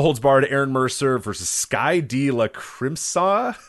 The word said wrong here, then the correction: Sky De Lacrimosa: I used to butcher that one all the time. It is Sky De Lacrimosa.